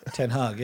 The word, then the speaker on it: Ten Hag: I'm very sorry if I mispronounce this, but the Ajax manager, is it Ten Hag? – Ten Hag, yep.